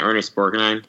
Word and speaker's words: Borgnine: Yeah, Ernest Borgnine.